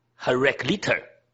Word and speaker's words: Heraclitus: S 不发音，所以叫做 Heraclitus。那如果按英文就要念 S，可是它其实那个 S 是不发音的。